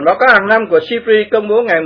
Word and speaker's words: SIPRI: Theo nhận định của Viện Nghiên cứu Hòa bình Quốc tế, viết tắt là SIPRI mà đọc là SIPRI,